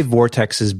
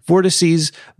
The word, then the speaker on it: vortices: Uh, and they realize they actually call it out on the website saying the correct grammatical pronunciation is vortices, but that's hardly ever used.